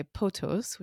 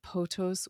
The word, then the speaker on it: pothos: And in the poem, he is said to be seized by potos, which I may be mispronouncing, but it's the ancient Greek word, P-O-T-H-O-S.